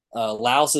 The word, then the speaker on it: Laos: I'm learning a lot of different pronunciations from countries around here that, you know, I learned today or yesterday that Laos is, you don't pronounce the S in Laos, it's just Lao.